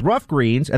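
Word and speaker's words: Ruff Greens: But now with Rough Greens, and that's spelled just like it sounds, R-U-F-F, it is perfect for dogs.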